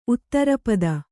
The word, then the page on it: ಉತ್ತರಪದ: uttarapada